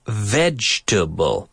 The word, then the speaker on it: vegetable: Words like vegetable. We don't say vegetable. We say vegetable.